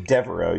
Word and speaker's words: Devereux: Devereux, I mean, with the name Devereux, you kind of have to go almost with a French pronunciation.